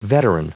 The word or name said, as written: veteran